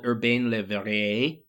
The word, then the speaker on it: Urbain Le Verrier: So there's a Frenchman called Urbain Le Verrier, who is officially...